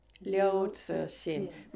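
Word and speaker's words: Liu Cixin: Liu Zixin, mainly translated Ken Liu.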